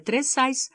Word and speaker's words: Тресайс: Для каждой газеты у него была припасена маленькая байка, разъясняющая, что фамилия Тресайс корнуэльского происхождения, а ударение в ней стоит на втором слоге.